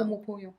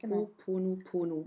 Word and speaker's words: Ho'oponopono: Kannst du es aussprechen? – Ah ja, warte mal. – Ho'oponopono. – Ja, genau. Ho'oponopono. – Ja, Ho'oponopono.